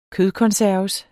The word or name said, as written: kødkonserves